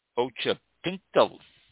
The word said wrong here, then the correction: Occhipinti: I think that's how it's pronounced. O-C-C-H-I-P-I-N-T-I. Ochipinti.